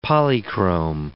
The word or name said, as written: polychrome